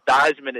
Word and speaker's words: Theismann: everyone will give you a blank look because the real family name is Theismann. – Oh. – They changed it to Theismann at Notre Dame to rhyme with Heisman, and they were trying to get the Heisman Trophy.